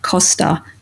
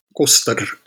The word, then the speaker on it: Koster: And we very much enjoy seeing – and you were at – okay, I'm going to have a crack at it – Costa. That's how I would say it. – Yes, Koster is how we pronounce it.